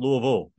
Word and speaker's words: Louisville: The thing is, you got to make the people usually screw up on the, they want to say Ville still, and it just sounds like you do the second half like the first half. You make it sound Louisville. It just sounds the same. – Well, it's like you're turning it's almost like not quite, it's not three syllables. It's, you guys turn it into like two and a half syllables.